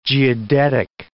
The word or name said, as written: geodetic